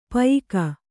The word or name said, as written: ಪಯ್ಕ